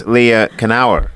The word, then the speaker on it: Leah Knauer: The hilarious Leah Knauer is with us, if Hello.